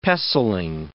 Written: pestling

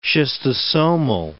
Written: schistosomal

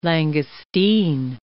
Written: langoustine

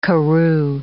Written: karoo

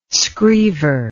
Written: screever

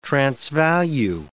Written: transvalue

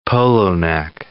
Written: polo-neck

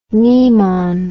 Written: mnemon

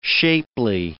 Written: shapely

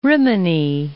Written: Rimini

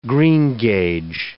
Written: greengage

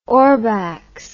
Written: ohrbachs

